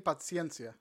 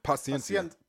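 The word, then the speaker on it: Pazienza: But yeah, that's a Bleed for This, the inspirational story of a world champion boxer, Vinny Pazzaniza? – Pazzaniza? – How do you pronounce this here? – Vinny Paciencia. – Paciencia. Paciencia. – It's in Italian, so the C has a T in front of it.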